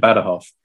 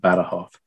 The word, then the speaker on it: Badajoz: This is exactly where we get Ciudad Rodrigo and Badajoz. I've just done that thing of going, here's the Spanish pronunciation, and I'm going to be hip and millennial and use the Spanish pronunciation.